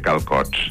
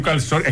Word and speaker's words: calçot: Lo único que no entiendo mucho es lo de comer calcots. – Pero que calcots, es calçot, es que no entens la cetre, una cosa que tenim també maca és la cetre trencada i no la pronuncia, diu calcots.